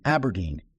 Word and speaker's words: abrdn: In open-end form, it is AIFRX, and Aberdeen, ABRDN, is how they really want us to pronounce it.